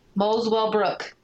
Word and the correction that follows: Muswell Brook: It's not spelled weird at all. It's Muswell Brook.